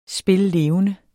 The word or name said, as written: spillevende